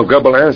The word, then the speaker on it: gobelin: Oh, I know what it is, the gobelin. – Oh, gobelin, is that how it's pronounced? – That's it.